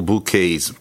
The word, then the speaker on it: bouquet: Stalls are full of colorful bouquets. Bouquet, хотя там «т» на конце, но это не произносится, потому что это букеты.